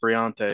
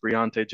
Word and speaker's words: Briante: And for Northside, Briante Johnson, it is pronounced Briante. It looks like Bronte, but it's Briante Johnson.